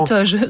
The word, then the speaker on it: toge: La Toge se prononce traditionnellement avec un O ouvert et bref. La toge comme la pomme.